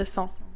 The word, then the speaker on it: sang: Blood is le sang in French, but I can never manage that pest of a nasal vowel right. Est-ce que tu peux prononcer le mot sang? – Sang.